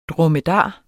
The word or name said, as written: dromedar